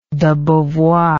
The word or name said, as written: debeauvoir